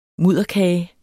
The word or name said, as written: mudderkage